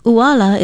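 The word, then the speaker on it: uala (ʻuala): Uala is spelled okina u-a-l-a with the glottal stop or okina at the beginning of the word, and it is pronounced uala.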